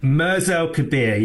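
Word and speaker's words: Mers El Kébir: For people who've never heard of this story, just tell us then what actually happened at, is it Mers El Kebir? Is that how you say it? – Mers El Kebir, yeah, in Algeria.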